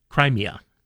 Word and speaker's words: Crimea: Crimea. Did I say it wrong? Crimea? – Crimea. – Thank you. – As in Crimea River.